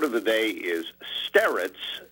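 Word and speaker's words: starets: S-T-A-R-E-T-S. S-T-A-R-E-T-S. Sterets, or one variation is to put a kind of Y sound in front of that last syllable, sterets, if that makes any sense.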